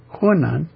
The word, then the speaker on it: Hunan: Hunan. – Hunan.